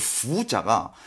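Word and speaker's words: 푸 (福): 에, 푸. F 사운드. – 네. F 사운드. 푸.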